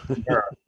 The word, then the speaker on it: Peter: And he pronounces my name like you're going to get a falafel sandwich on a pita bread instead of Peter.